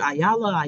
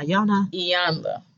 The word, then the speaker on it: Iyanla: Because I actually got that from, I watched an episode of, how do you say her name? Ayala? – Iyanla.